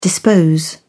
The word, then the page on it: dispose: British English